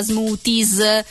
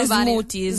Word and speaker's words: smoothies: Perché praticamente, innanzitutto, vabbè, non si sa come si pronuncia smoothies, smoothies. – Ma cioè, secondo me è smoothies. – Smoothies, esatto.